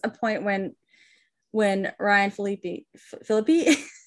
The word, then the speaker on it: Phillippe: But yeah, he, let's he talk about a lot of this about Ryan Phillippe, who Felipe. – Stop. I thought my sister told me that that's how it was pronounced, and until this year, I pronounced it like that.